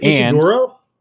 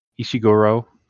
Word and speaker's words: Ishiguro: Is it Ishiguro? – Ishiguro, close enough.